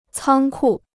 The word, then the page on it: 仓库: cāng kù